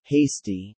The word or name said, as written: hasty